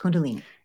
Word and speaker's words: Kundalini: I think it's Kundalini yoga. She's a Kundalini yoga teacher. – Yes. – Kundalini.